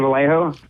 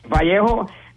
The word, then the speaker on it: Vallejo: Well, dude, it's like, you know, I was telling people it's, you know, for mostly our fan base and demographic, it's AJ Vallejo. You know, for the Latinos, it's Vallejo. And then if you're from Alabama, it's Valley Joe.